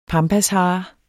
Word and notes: pampashare: dansk

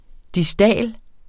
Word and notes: distal: [ diˈsdæˀl ]

